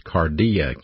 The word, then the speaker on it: kardia: In the New Testament, the mind is pronounced in the Greek terminology, the nous, the N-O-U-S. And if it's the heart, it's pronounced cardia, K-A-R-D-I-A, and we get an English word from that, cardiology or cardiac.